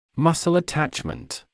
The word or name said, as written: muscle attachment